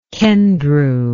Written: Kendrew

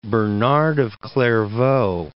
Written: Bernard of Clairvaux

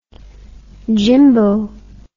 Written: jimbo

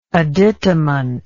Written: additament

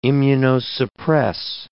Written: immunosuppress

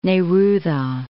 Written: Neruda

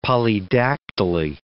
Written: polydactyly

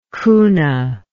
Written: cuna

